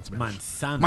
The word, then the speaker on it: Manzana: Maranza? Marana? How do you say that? – Manzana. – Manzana! See, I got the first letter right.